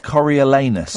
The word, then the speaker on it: Coriolanus: Coriolanus. – Coriolanus.